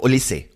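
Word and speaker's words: Olise: Wie wird der richtig ausgesprochen? – Olisse. – Mit H hinten, ja. Ja, also mit H, so Olisse.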